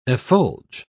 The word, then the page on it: effulge: British English